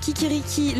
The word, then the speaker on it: Kikeriki: Kikiriki, le podcast, ça s'écrit K-I-K-E-R-I-K-I.